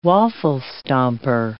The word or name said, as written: waffle stomper